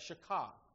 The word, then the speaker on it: shakah: The Hebrew word in the Old Testament is this word, shakah. That's how they say you're supposed to pronounce that, shakah.